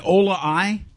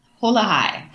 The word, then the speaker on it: Hola Hi: Ola I? – Hola Hi. – Hola Hi. Okay, because I know in Spanish you don't pronounce the H.